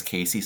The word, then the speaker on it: Casey: My last name is Casey.